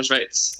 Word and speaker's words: Schweiz: jag tror jag säger rätt nu, Schweiz. – Ja, Gustav Fridolin hade ju lite problem med det för några år sedan, men det stämmer. Schweiz.